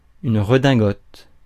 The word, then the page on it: redingote: France; [yn ʁə.dɛ̃.ɡɔt]